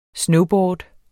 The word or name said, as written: snowboard